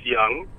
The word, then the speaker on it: Young: My name is Young.